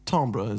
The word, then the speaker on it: timbre: There it is, that classic timbre is how I pronounce it, but that's because I'm fluent in French.